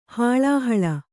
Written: ಹಳಹಳಿ

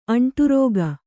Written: ಅಂಟರಿಕೆ

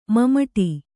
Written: ಮಮಟಿ

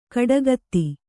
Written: ಕೊಡಗಿತಿ

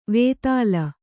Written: ವೇತಾಲ

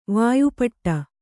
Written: ವಾಯುಪಡೆ

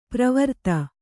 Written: ಪರಿವರ್ತ

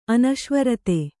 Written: ಅನಶ್ವರತೆ

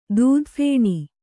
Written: ದೂದಫೇಣಿ

